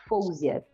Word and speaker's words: Faouzia: que chama Fouzia. Se escreve Faouzia.